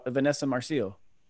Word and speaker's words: Vanessa Marcil: And you co-starred with Vanessa Marcil.